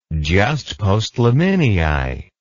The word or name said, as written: jus postliminii